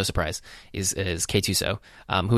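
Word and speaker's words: K-2SO: Yeah, the official pronunciation guide is K2SO, but it was weird.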